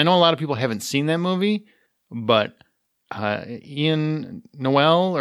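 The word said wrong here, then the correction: Y'lan Noel: or Yolan Noel. Sorry.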